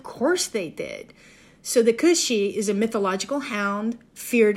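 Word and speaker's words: cù-sìth: I have some idea how to pronounce Welsh, but it's C-W-N and then another word, A-N-N-W-N. Cwn Anhwyn, possibly. So if we go to the Scottish folklore, the Cushy, which is C-U-S-I-T-H,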